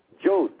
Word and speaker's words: George: Instead, George is pronounced Joj, as you would spell it J-O-J.